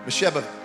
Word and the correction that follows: Mephibosheth: uh, uh it's Mephibosheth. I almost said it right.